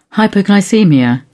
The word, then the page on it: hypoglycaemia: British English